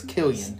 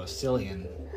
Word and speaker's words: Cillian: I think it's Killian. – I just saw Cillian. – That's what I'm saying. Like, I've heard it pronounced both ways. So it's either Killian or Cillian Murphy.